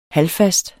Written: halvfast

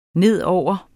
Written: nedover

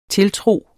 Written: tiltro

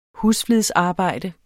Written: husflidsarbejde

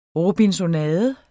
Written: robinsonade

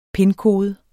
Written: pinkode

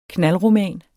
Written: knaldroman

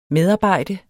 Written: medarbejde

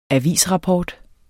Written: avisrapport

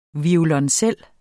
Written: VIOLONCELLEN

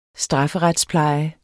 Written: strafferetspleje

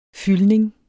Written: fyldning

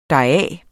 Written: deraf

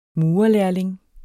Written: murerlærling

